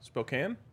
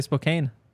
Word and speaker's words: Spokane: Spokane. Let's ride. Yes. LOL. – Spokane? – Spokane. – I guess on both of them. – That's how everyone says it. I wonder if people say Spokane.